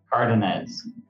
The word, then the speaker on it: Cardenaz: Oh, I didn't do your last name right. Tell us how to say your last name. – Cardenaz. – Cardenaz.